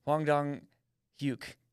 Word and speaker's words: Hwang Dong-hyuk: It's Hwang Dong-hyuk. – I think you're pretty close, yeah.